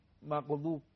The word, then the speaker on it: مغضوب: یا غیر المغضوب علیهم ولا الضالین، مغضوب زاد دیگه، صاد زاد، اینم درشت تلفظ میشه. – این زاد ببینید این تماس کناره زبان با دندون‌های آس، این زبان کنارش با این دندون‌ها، این طرف اون طرف فرقی نداره.